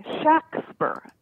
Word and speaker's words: Shakespeare: But actually, if you look at the spelling of his names in legal documents, it was pronounced with a short A, Shakespeare, S-H-A-X-P-E-R, S-H-A-C-K-S-P-E-R, you know, like that, variations that indicate a short A.